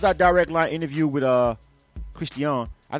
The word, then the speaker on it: CRIST'IANI: But it's CRIST'IANI, not CRISTIAN E.